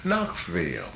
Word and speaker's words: Knoxville: Starts with a K. – With a K. – But it's pronounced with an N. K-N. O-X. – Knoxville. – Yeah, okay. – Of course. Knoxville.